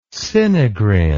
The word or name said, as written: sinigrin